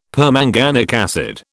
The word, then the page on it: permanganic acid: British English